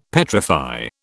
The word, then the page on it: petrify: British English